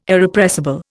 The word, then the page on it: Irrepressible: Indian